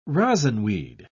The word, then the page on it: rosinweed: British English